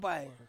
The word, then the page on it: pai: Ansus